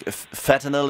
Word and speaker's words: fentanyl: heading more towards synthetic drugs like fentanyl.